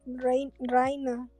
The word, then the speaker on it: Reiner: Seque y el Reina, Reina, así lo dicen ellos, pero pues no sé. se escribe Reiner.